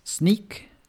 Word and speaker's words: Snyk: Y la primera es Sneak, em, se escribe S-N-Y-K.